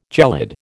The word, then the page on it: gelid: American English